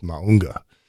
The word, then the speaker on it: Mauga: This guy is, it's spelled M-A-U-G-A, but he says you put an N in front of the G, so it's Maunga.